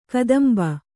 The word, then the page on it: ಕದಂಬ: kadamba